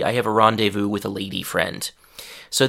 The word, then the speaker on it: rendezvous: So in French, the pronunciation is super similar, rendezvous. In English, uh, we can't really say that French R, so we just say rendezvous.